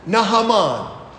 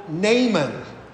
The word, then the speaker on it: Naaman: The main character of our reading today is Nahaman. Now, you may have heard him growing up called Naaman, but that's really not the pronunciation.